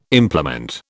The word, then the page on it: implement: British English